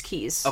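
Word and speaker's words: Kyes: Nancy, I don't know if it's Kies or Keys. No, it's K-Y-E-S. So I believe it's Keys.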